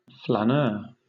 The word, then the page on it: flaneur: British English